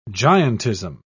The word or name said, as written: giantism